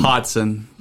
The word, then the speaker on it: Hodson: That's actually not how you say my last name. – Scoot your mic closer to your all face right. And say how do you say your name? – Hodson. – Hodson. – Yeah, so H-O-D-S-O-N, Hodson.